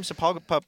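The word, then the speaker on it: Sopoaga: Is that how you pronounce his name? – Sapaga. – Yeah. Okay, that's what I said.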